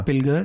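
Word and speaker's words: Pilger: मेरा रिकमेंडेशन एक documentary film है, एक इंटरनेशनल वॉर जर्नलिस्ट हैं जॉन पिलगर, जॉन पिलजर भी कई लोग उन्हें प्रोनाउंस करते हैं,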